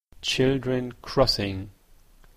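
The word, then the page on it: children-crossing: Canadian English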